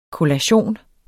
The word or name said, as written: kollation